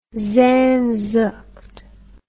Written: sehnsucht

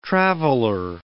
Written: traveler

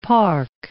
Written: park

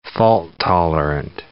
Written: fault-tolerant

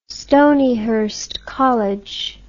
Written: Stonyhurst College

